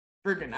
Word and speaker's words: Bergen: Am I saying Bergen or Bergeron? I just suddenly had like a oh my god, I've been saying her name wrong this whole time. Bergen, I was right.